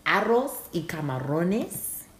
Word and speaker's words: arroz y camarones: I always got, it's called, and because I know Spanish, so I'm going to say this correctly for y'all. It's arroz y camarones.